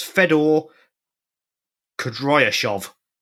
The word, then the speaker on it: Fedor Kudryashov: Is Fedor Kudryashov, I believe. – Good work on the pronunciation.